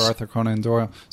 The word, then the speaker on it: Arthur Conan Doyle: I like Sherlock Holmes, the detective books by Arthur Conan Doyle, if I pronounce it correctly. – So Arthur Conan Doyle.